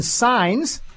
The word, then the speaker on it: Sines: Now we're going to go to our first guest today, however, is Jonathan Sines. And I hope I'm pronouncing that correctly. he is the president of Texas Values, an organization that advances family values in the state of Texas, the way we try to do here in Washington at Family Research Council. Jonathan, are you with us? – I am. It's great to be with you. You got the pronunciation dead on.